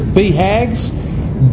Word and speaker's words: BHAGs: That's how to pronounce it. BHAGs.